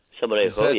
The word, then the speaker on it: Salmorejo: I noticed, I don't know if I can say this right, Salmorejo. – Salmorejo, yes.